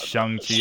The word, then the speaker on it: Shang-Chi: So it's not Shang. It's not Shang. Apparently we're American, so we said it wrong. So it's Shang-Chi, apparently.